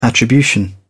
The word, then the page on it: attribution: British English